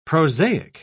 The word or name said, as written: prosaic